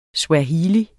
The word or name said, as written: SWAHILI